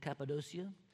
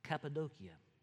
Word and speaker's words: Cappadocia: And I've always said Galatia and Cappadocia. But I learned that I was always pronouncing it wrong. So, Cappadocia. There you go.